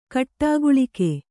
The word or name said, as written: ಕೂಡುಗಲಕ